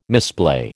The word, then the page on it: misplay: American English